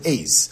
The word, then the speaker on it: adze: A matzad is an ace, right? So he's working, I think that's how you pronounce it, A-D-Z-E.